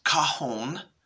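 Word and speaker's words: cajón: And uh, it's pronounced cajon. Um, it's spelled C A J O N with an accent on the O. And uh, the Spanish uh, pronunciation is cajon, and it's box, crate, or a drawer.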